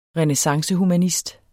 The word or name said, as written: renæssancehumanist